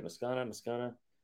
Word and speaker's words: Moscona: Moscona. Why do I always say his name wrong? – Scone. Like a scone.